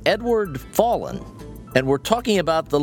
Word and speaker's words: Fallon: It's Edward Fallon, like Fallon. – Jimmy Fallon.